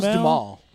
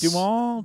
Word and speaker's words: Duhamel: How's his name pronounced? Duhamel? – That was Dumal. – It's Dumal.